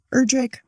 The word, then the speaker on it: Erdrich: I did not explain how Erdrich rhymes with brick, not witch. I did not trust my voice for that either.